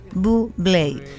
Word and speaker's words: Bublé: Bublé, que se dice como Noguera. – Bueno, según la campaña publicitaria de su lanzamiento como artista, decía, se pronuncia Bublé.